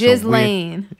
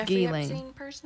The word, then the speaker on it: Ghislaine: This Jizz Lane. – Is that really her name, Jizz? Jizz Lane Maxwell? – That's what I call her, man. Ghislaine. It's pronounced Ghislaine.